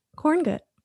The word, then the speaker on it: Korngut: And joining us today is Josh Korngut. Did I say that correctly? – Yeah, I feel like there's so many ways to say it, and I like the way that you chose to.